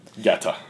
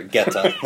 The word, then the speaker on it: Goethe: Goethe, is how you say it. – Oh, sorry, Goethe.